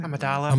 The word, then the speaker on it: Amidala: Amidala, am I saying that right? – Amidala. – Amidala. – Thank you. Amidala. – Amidala sounds too much like armadillo.